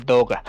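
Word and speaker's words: Doga (動画): Creo, Gander, si es la palabra que yo creo que es, y si un 90% seguro que sí es, no se dice Doga, se dice Doga. Porque es, la palabra japonesa para vida. Que literal significaría este imagen en movimiento. – Do es del kanji de movimiento y Ga el de imagen.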